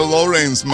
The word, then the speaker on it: Lawrence: Dice la viejeva, que se pronuncia Lawrence, Massachusetts, donde ella vive. No Lawrence, como dijo el gran Durán.